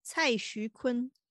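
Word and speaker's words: Cai Xuquan (蔡徐坤): And correct me if I pronounced this wrong. – Cai Xuquan. – Yes, thank you.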